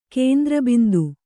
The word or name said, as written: ಕೇಂದ್ರಬಿಂದು